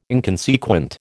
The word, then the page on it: inconsequent: American English